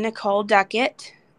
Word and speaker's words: Nicole Duckett: My guest today is my friend Nicole Duckett.